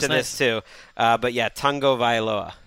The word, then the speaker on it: Tagovailoa: You are supposed to say tongue to start. – Oh, that's cool. – So tongue Tua? – No, Tagovailoa.